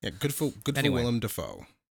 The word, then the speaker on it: Willem Dafoe: Dude, his name is Willem Dafoe. It's spelled out Willem Dafoe. Yet there's that video of him when he was like 19 announcing his name as William Dafoe.